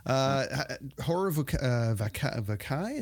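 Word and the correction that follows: Horror Vacui: Horror Vakui.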